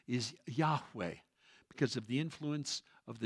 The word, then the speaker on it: Yahweh: Sometimes you've heard God's name pronounced Jehovah. You've heard that before? Well, there's no J or V in the Hebrew alphabet, so they could not have pronounced it Jehovah.